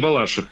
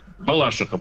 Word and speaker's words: Балашиха: У нас город поделился, у нас историческое название Балаших. Ага. Точнее, Балашиха, правильно. – Нет, да. Все-таки правильно Балашиха, прости, пожалуйста. – Потому что изменилось ударение за годы.